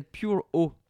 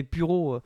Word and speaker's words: Pure O: Celui-ci s'appelait Pure O. Parce que je ne prononce pas à la française, ça fait Puro.